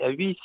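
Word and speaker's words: Haüy: Oui, l'association Valentin Huy, Huy c'est H-A-U-Y, alors vaut mieux le prononcer que le lire.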